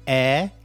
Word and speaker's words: heir: But also the word honest, also the word heir, H-E-I-R, which is basically the person who inherits your property, your belongings. Heir is pronounced air, like the air in the room, A-I-R. But the spelling is H-E-I-R.